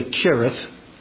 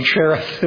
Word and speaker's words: Cherith: Now, I am aware that the proper pronunciation of this word is really Kirith, Kirith. But you'll excuse me, won't you? I have a daughter named Cherith. That's the way she pronounces it. That's the way we pronounced it for her. And so that's the way I'm going to pronounce the name of this brook.